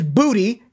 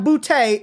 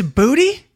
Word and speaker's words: Boutte: And it is Booty. It is not Boutte. It's Booty. – It's Booty? – It is Booty. We have been saying it wrong all year.